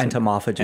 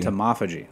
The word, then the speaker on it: entomophagy: I mean, so a lot of the information that's out there about entomophagy, how do you pronounce entomophagy. – It? – Entomophagy.